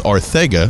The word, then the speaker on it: Arcega: JJ is totally fine if you say Arcega, but he clearly told us Arcega.